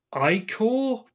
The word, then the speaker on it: ichor: No, Icor. I C H O R. It's pronounced "icor."